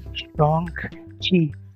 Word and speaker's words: Shang-Chi: Hay que, eh, durante la semana, eh, el actor principal tuiteó que la pronunciación correcta, de hecho, es Shang-Chi o no y creo que toda la prensa ha estado tratando de corregir el error de pronunciación. Ah, pero sí, bueno, ese, ese dato.